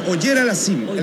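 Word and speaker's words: Auger-Aliassime: Pues es, disculpe la pronunciación, Auger Aliassime. – Es Oyer Aliassime, el canadiense. Félix Oyer Aliassime.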